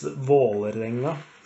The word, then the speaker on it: Vålerenga: It's in the Oslo dialect, it's Vålerenga, which is in a clean Norwegian way of pronouncing it. It's Vålerenga.